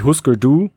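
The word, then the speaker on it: Hüsker Dü: Äh, jetzt, da sind wir wieder bei Umlauten übrigens, weil äh, Husker Du äh, heißen in Amerika natürlich Husker Du, weil es niemand aussprechen kann.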